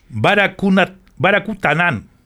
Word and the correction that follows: Baracunatana: perdón, Baracunatana.